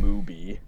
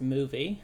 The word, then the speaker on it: Mubi: It premiered on Mubi. It had a very limited release. – There you go. – Yeah. – Actually, it's pronounced movie.